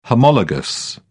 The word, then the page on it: homologous: British English